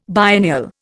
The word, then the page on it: biennial: Indian